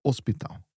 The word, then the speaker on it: hospital: Now, the letter H, remember that this letter is never pronounced if it's the first letter of a word in Portuguese. No? Just like the word hospital is hospital, even though it's spelled with an H.